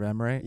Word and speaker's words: Emre: Is it Merv Evre? – Yeah. Emre. – Yeah. Yeah. I don't know. – Merv Emre.